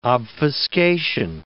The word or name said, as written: obfuscation